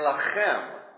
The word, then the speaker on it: Lechem: And the thing that's interesting, in Hebrew, Bethlehem, it's pronounced, it's actually two words. It's two words brought together. It's Beit and Lechem. Beit is house of, Lechem is bread or life.